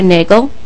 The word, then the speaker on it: Nagel: My name is Sarah Nagel, rhymes with bagel.